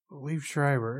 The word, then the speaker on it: Liev Schreiber: Liev Schreiber. – There we go. That's how you pronounce it.